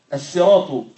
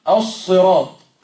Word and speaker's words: السراط: أصلها السين. بعض العرب ينطقها الصراط بالصاد، لأن السين جاورت أو جاء بعدها حرف الاستعلاء الوطاء فتأثرت فأخذت من استعلائها وتفخيمها فتحولت إلى صاد. إذن هذه لغة وهذه لغة، لكن أيهما الأصل؟ السين.